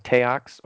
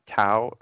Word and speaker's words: Taox: This is where we learn that their teacher, Teox, or Tao, is, depending on how some people pronounce it, T-A-O-X, this is the person who is kind of like their teacher.